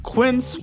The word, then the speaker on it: Quince: Today Explained support today comes from Quince, which rhymes with since, but is spelt with a Q-U.